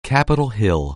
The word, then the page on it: Capitol Hill: anglais